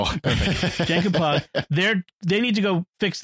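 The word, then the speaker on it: Jankom Pog: Jankum Pog. – I was going to say switch the J and the P. – Jankum Pog. Think Janky Pig.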